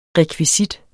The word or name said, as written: REKVISITTEN